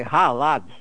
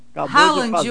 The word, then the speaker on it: Haaland: É aquele famoso lá, não sei como é que pronuncia aquele nome. O mais famoso da Noruega é Haaland. É isso, nosso. – Haaland. O Edson Palma falou que é Haaland. – Haaland.